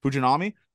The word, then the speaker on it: Fujinami: But you were speaking about that with the pitcher Fujinari. How do you pronounce it? – Fujinami.